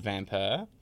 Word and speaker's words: Vampyr: um, called Vampire. I'm saying it like that because it's spelt V A M P Y R.